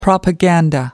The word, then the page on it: propaganda: American English